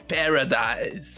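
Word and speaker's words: Paradis: O, o, o Matt Parades, acho que assim que se pronuncia, se eu errei, desculpa, por É, mas... Eu acho que, tipo, acho que é, fala como se fosse paraíso mesmo. Matt Paradise. Paradise.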